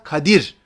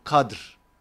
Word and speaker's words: Kadir: Biz telaffuz ederken tabii Türkçe'de söyleniş kolaylığı olarak Kadir, D ile R'nin arasına i koyarak söylüyoruz. Ama Arapça orijinali nedir? Leyletul Kadr.